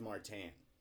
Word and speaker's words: Martin: Is actually a trick question because it's Martin Brodeur. Greatest goalie ever. Patrick Waugh, get out of here. Marc-Andre Fleury. No one needs you anymore. It's Martin Brodeur. Martin. Spelled Martin, but is Martin.